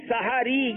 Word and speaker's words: سحری: سحاری. ہا پر زبر ہے. – ہا پر زبر ہے. سحاری یہ ٹھیک ہے. سحری کرنے جا رہے ہیں یا سحری کر رہے ہیں, صحیح تلفظ ہے سحاری.